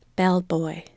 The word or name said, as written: bellboy